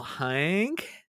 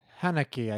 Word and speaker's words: Haneke: Directed by Michael Haneke. Is that how we say it? – Haneke, I think, is. – I say Haneke. – Okay. Haneke, Michael Haneke.